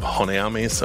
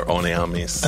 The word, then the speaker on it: Honnêamise: You just watched it, so is it Wings of Honeamis or Oneamis? – I think it's I think the H is silent.